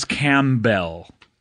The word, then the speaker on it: Campbell: It's written like Camp Town Ladies. – Right. – But a bell instead of Town Ladies. – Right. – Yeah. – That's how I remember how to spell it, for sure. – Right, but it's Campbell.